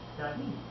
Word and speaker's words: chadey: In Chaldean, the word is spelled C-H-A-D-E-Y and pronounced Shadi, meaning fresh.